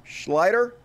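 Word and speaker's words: Schlichter: This is Kurt Schlitter? Schlitter? I don't know how you pronounce it. Schlitter. Schleider, there we go.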